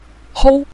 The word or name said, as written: hou5